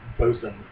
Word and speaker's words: Boatswain: Apparently on here, though, it says it's supposed to be pronounced bosun.